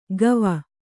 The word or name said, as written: ಗವ